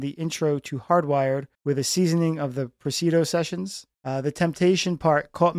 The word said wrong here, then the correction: Presidio: Am I saying that right? – It's Presidio. – Presidio. Okay. Maybe you should read this one, but we're already into it.